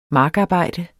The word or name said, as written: markarbejde